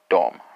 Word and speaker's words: dem: Well, we're working with our komma r, but we need a pronoun, so that's going to be dom, right? Dom, but it's spelled D-E-M.